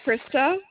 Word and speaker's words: Krista: Krista. – Can you please spell that for me, please? – K-R-I-S-T-A.